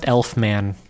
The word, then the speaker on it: Elfman: It's pronounced Elfman.